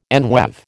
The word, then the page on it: enweave: American English